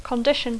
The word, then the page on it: condition: English